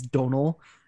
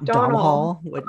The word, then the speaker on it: Domhnall: So now it's like clicked in with me as Donal, not Domhnall.